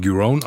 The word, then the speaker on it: Giron: Also er spricht sich Giron aus und Alexander Zverev führt.